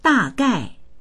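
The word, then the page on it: da4gai4: Mandarin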